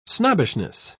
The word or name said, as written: snobbishness